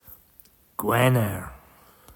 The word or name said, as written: Gwener